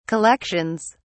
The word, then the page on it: collections: British English